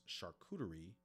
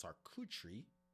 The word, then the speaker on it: charcuterie: But in modern day speak, when we say charcuterie, which here in the United States is pronounced charcuterie, whereas the French pronunciation is charcuterie, we are referring to cured meat.